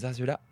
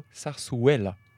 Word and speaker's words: zarzuela: Alors, je tiens à dire d'abord que nous avons toutes mal prononcé parce que les deux Z se prononcent et le U se prononce ouais. Donc on dirait plutôt zarzuela.